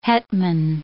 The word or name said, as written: hetman